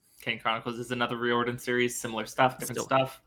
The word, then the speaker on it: Riordan: Fantasy novels written by Rick Riordan.